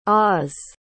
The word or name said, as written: ahs